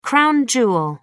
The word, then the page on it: crown jewel: American English